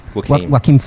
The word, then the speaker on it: Joaquin: The amount of shit that Joaquin... Is that how you spell it? Is that how you say Joaquin? – Joaquin Phoenix.